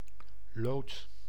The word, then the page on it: lood: /loːt/